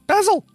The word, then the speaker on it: basil: Basil simple, fresh basil simple. Basil would be the right way to pronounce that.